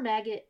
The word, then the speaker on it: Maggot: Farmer Magnet, Jesus. How many times can I mispronounce Farmer Maggot's name? – I love it. – Farmer Maggot and Gildor the Elf.